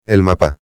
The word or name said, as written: el mapa